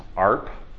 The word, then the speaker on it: ARP: The answer lies in something called the Address Resolution Protocol, which we typically pronounce ARP.